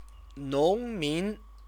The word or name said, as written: nongmin